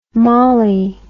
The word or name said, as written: mallie